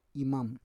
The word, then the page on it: imam: Roon